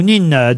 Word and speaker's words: Nin: Jean-Luc Nain ou Nine de Pau. – C'est pas pareil. – Oui. Oui. Ça s'écrit N-I-N. Alors, vous prononcez comme vous voulez.